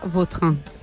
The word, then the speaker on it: Vautrin: Más puede ser más la pronunciación. – Elegante. – La original francesa, Vautrin.